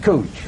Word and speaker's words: Couch: I pronounce it Cooch.